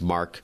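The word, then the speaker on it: Marc: And my name is Mark, spelled with a C.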